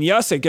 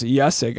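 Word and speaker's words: Juszczyk: I mean, Yusick, it's one of those Polish names. It's J-U-S-Z-C-Z-Y-K. I'm going to say that one more time. That's J-U-S-Z-C-Z-Y-K, and it's pronounced Yusick, I believe.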